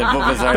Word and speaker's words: Vuvuzela: How do you say the Vizvela? – Vizvela. – V-U-V-U-Z-E-L-A. – The Vuvuzela. Is it Vuvuzela?